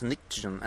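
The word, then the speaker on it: pnictogen: It sounds like nitrogen, but it's not. It's nictogen, and it's actually spelled with a P.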